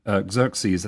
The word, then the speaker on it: Xerxes: And the next question is, which Xerxes, that's how you pronounce it, right? X-E-R-X-E-S.